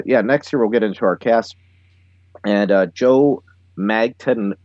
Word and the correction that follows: Mantegna: I always forget how to pronounce it. It's Mantegna. – Mantegna, okay. Yeah.